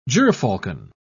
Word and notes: gyrfalcon: British English

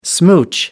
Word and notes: smooch: British English

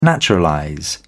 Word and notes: naturalize: British English

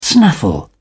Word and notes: snaffle: British English

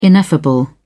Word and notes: ineffable: British English

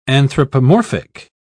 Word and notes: anthropomorphic: British English

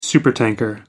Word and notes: supertanker: American English

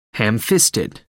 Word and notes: ham fisted: American English

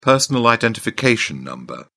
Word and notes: personal identification number: British English

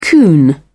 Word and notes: coon: British English

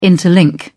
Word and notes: interlink: British English

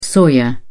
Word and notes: soya: British English

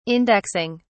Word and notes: indexing: British English

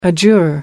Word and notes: adjure: American English

